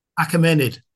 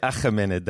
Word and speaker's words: Achaemenid: I heard you say Achaemenid, that's why. I was like, hey, that's me, because I can speak Arabic, and so that is a pronunciation that I don't hear too many people saying.